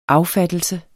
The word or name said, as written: affattelse